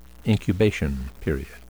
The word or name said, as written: incubation period